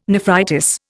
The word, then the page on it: nephritis: Indian